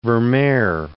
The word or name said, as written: Vermeer